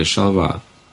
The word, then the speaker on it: Шалва: Когда его спросили, как вас называть, Шалва или Шалва? Он говорит, а вот как чувствуете, как вы хотите? В грузинском нет ударений, в грузинских, как вам нравится, вот так называете, все.